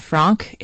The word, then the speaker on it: Franck: It's by the composer Gabriella Lena Franck.